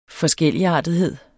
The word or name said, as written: forskelligartethed